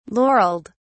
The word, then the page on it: laurelled: British English